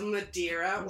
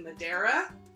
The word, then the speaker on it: Madeira: And then, of course, the islands of Madeira, or as I think they pronounce it, Madeira, and the Azores. As I was, like I said, I was watching the Wine show season 3, and they're in Portugal, and I think they were pronouncing it Madeira. – But maybe that's just Madeira. Because they're British. – True. Actually, I think a true Portuguese person even said Madeira.